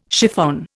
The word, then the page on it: chiffon: Indian